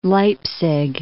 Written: Leipzig

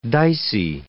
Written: dicey

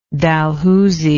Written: Dalhousie

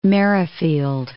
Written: Merrifield

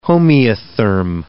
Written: homeotherm

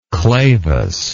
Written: clavus

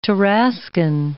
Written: Tarascan

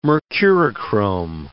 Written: Mercurochrome

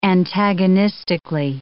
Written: antagonistically

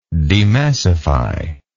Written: demassifier

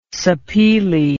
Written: sapele

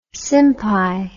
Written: simpai

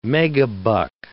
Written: megabuck

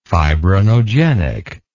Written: fibrinogenic

